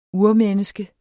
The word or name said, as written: urmenneske